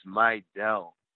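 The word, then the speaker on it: Mejdal: Well, one thing that fans will get familiar with, Justin, is how to say his name. You got it. It's Meidel. It's a tough one.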